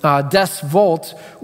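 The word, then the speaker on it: Deus vult: And at the end of this long call, he says in Latin, he cries out in Latin, and I'll spell it for you, but it's D-E-U-S and then V-U-L-T, Des Volt, which basically means God wills it.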